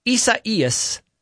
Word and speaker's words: Isaias: Okay, so apparently Isaias is the Spanish and Portuguese form of the biblical name Isaiah.